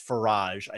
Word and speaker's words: Farage: But I wanted to open with Brexit and the speech that Nigel, I guess it's Farage, I think that's the way you pronounce it.